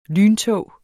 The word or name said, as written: lyntog